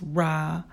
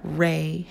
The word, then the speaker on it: Ra: Ra, pronounced Ra and not Ray,